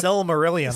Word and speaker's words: Silmarillion: We got that sound when I told her that we were got the rights to the Silmarillion. Or I'm probably saying that incorrectly. – The Silmarillion, sir.